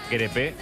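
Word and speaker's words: Crépeau: Dice Marquinhos que se dice crepe, como un crepe de Nutella, de nocilla.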